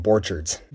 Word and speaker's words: Borchardt: It's like Orchards with the B on the beginning, Borchardt.